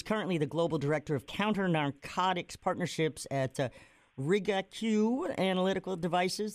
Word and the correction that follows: Rigaku: Did I say that right? Close enough? – Rigaku. – Yeah, accent on the wrong syllable.